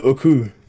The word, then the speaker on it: Ooku: How'd you say it? – I pronounce it Ooku.